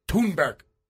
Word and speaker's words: Thunberg: And I said her name wrong. Sure, you look at it, it looks like Greta Thunberg, but mm-hmm. that's not how it's pronounced. – It's not Greta? – It's Greta. – Hmm. – And her last name is Thunberg.